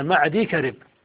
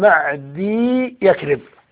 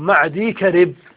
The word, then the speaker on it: معدي كرب: معدي كرب من الأسماء المعروفة عند العرب، ومنهم الصحابي معدي كرب، ومعدي كرب نطقه الصحيح هكذا معدي كرب، ومن الخطأ أن يقال معدي يكرب بياءين، هذا خطأ، هو معدي كرب.